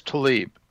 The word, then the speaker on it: Tlaib: Political upheaval over, is it Tlaib? Is that how you say it? I don't watch TV. – She says Tlaib.